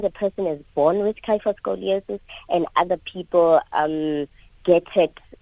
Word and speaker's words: kyphoscoliosis: What is kypho or kyphoscoliosis? Am I saying it correctly? – Yes, it's kyphoscoliosis, Asanda.